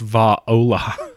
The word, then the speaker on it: Valhalla: If this tail grabs you by the throat and holds you under the water, you're going to Mexican Valhalla. – It's pronounced Va-ola.